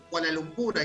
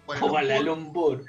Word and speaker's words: Kuala Lumpur: Dicen que han liberado mosquitos en Kuala Lumpur, ahí está, lo dije bien, Kuala Lumpur.